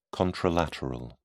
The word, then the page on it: contralateral: British English